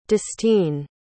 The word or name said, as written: dustine